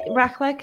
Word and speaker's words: raclette: which is how do you say that cheese? Warm raclette? – Raclette? I'm not sure. – Raclette Swiss cheese. Raclette, thank you.